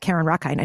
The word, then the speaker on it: Carin Rockind: On Instagram, it's at Carin Rockind. I know my name is spelled funny, but C A R I N R O C K I N D.